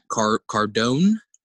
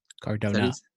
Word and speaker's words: Cardona: What I do see happening is someone like a which I'm going to pronounce the name wrong. I'm horrible with names. I'm sorry. Matt Cardona. – Cardona. – Cardona, sorry. Matt Cardona.